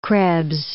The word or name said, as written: Krebs